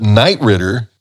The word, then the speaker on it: Knight Ridder: That's a news source publication, and there are many Knight Ridder publications throughout the United States.